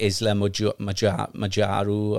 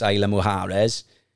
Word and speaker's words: Isla Mujeres: It's spelled X-E-L and then H-A. We did the Isla Mujeres, which looks like Isla Majaru or something. It looks like that, but it's pronounced Isla Mujeres.